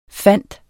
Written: findes

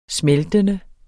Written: smeltende

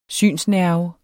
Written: synsnerve

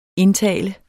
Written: INDTALES

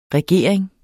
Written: regering

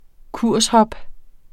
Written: kurshop

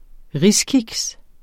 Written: riskiks

